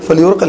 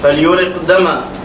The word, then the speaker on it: فليهرق: نعم فليهرق دما. نعم فليورق الهاء يعني لا تنطق فليورق دما. نعم فليورق دما.